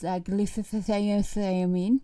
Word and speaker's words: glutathione: The other is glutathione, and that's how you pronounce it officially.